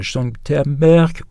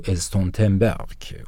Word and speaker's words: Stoltenberg: Mercredi 17 mai 2017, pour montrer que l'OTAN s'associait à la Journée internationale contre l'homophobie, le secrétaire général Jens Stoltenberg ou Stoltenberg, on peut prononcer ça de deux façons,